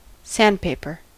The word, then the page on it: sandpaper: American English